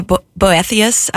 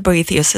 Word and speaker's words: Boethius: Hopefully I'm pronouncing that correctly. If not, you can correct me. – Yeah, Boethius. – Boethius. – Well, I've heard Boethius as well, so I think you're okay with either one.